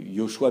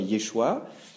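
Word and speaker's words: Yeshua (ישוע): Cette vocalisation va se généraliser, c'est-à-dire que le prénom Josué ne sera plus prononcé Yoshua, mais Yeshua.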